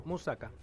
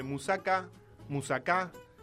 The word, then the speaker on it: Moussaka: Moussaka. – Un pastel de berenjenas y carne. – Bien, es correcto. Moussaka, se dice y se escribe de muchas maneras.